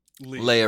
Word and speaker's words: Leah: I like is it Leah or Leia? I don't know how to say it. – Leah Remini, I believe, is the exact pronunciation.